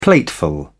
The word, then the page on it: plateful: British English